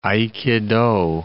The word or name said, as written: aikido